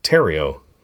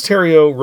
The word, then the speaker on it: Terrio: He's writing it with Chris, rhymes with Ontario, Chris Terrio. So it's just the end of Ontario. There we go. I looked up how to pronounce it. Chris Terrio, yes. So Chris Terrio wrote the screenplay for a movie called Argo, which is a movie that I thought was really well done, well crafted.